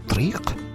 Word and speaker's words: طريق: Tariqa, qui est sur la racine de tariq, qu'on prononce en marocain triq, le chemin.